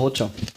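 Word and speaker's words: Hoxha: So spricht man denn Hotcha. – Aus. Hotcha. – Enver Hotcha.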